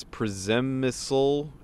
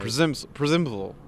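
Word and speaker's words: Przemyśl: It starts with a P-Z. It's Przemysl. – And they all start with P-Z over there. – There's some accents. I don't know where the emphasis is, but it's Przemysl. It's right. Przemysl.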